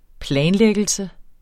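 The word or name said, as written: planlæggelse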